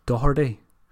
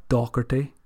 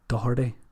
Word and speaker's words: Doherty: Very happy to see the legend that is Matt Doherty score a 94th minute winner. Listen to that carefully as well, folks. It's Doherty, not Doherty, not Dirty, Matt Doherty.